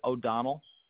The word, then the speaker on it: O'Donnell: Anyone, Edward O'Donnell. That's O-D-O-N-N-E-L-L. – Spell it again, your last name. – O-D-O-N-N-E-L-L.